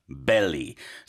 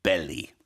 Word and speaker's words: belly: Okay, so first we have ballet dancer, ballet. Then we have belly dancer, belly. So you can hear the difference in the sounds of the vowels. Ballet, belly.